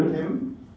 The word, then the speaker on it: Kim: My name is Peter Kim. – Can you spell that? – P-E-T-E-R, last name K-I-M.